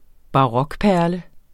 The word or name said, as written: barokperle